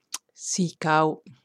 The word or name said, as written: Sea cow